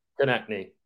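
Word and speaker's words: Konecny: So like, it always kind of screws me up because it's like, Konechny or whatever, however you pronounce his name. – Konechny.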